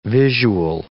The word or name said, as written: visual